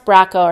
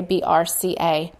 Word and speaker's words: BRCA: It's pronounced BRCA, or sometimes they say BRCA.